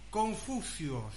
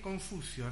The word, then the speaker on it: Confucio: Confucio, ¿sí? Confucio, así se pronuncia.